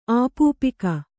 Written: ಆಪೂಪಿಕ

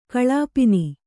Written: ಕಲ್ಪನೆ

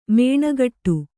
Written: ಮಿಣುಗುಟ್ಟು

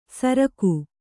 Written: ಸರ್ಗ

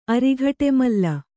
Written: ಅರಿಘಟೆಮಲ್ಲ

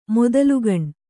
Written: ಮೊದಲುಗಣ್

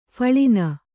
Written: ಫಳಿನ

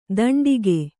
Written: ದಂಡಿಕೆ

